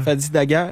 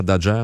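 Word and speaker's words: Fadi Dagher: Et un des témoignages les plus touchants, c'est le chef du SPVM, Fadi Dadjer, on le prononce-tu comme ça, Dadjer? – Fadi Dadjer. – Dadjer.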